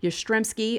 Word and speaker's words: Yastrzemski: Yastrzemski of the 1967 Red Sox.